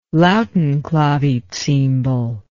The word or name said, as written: lautenclavicymbal